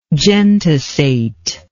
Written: gentisate

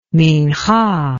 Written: minchah

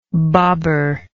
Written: babar

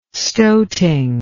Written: stoating

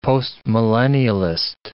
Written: postmillennialist

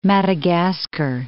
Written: Madagascar

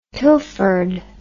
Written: pilfered

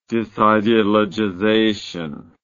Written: disideologization

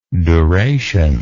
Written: durational

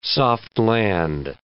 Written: soft-land

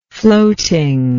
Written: floatingly

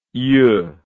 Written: yeux

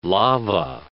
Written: lava

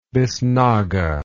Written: biznaga

